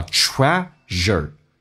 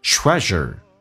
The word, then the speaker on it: treasure: 这个单词很难发，叫 treasure，有一个发音叫 z，有点像日本人的日啊 z，轻轻的卷下舌头 z， treasure， treasure are the moments you laugh with your loved ones， loved ones 你所爱的人，就是跟你所爱的人在一起欢笑的那个时刻。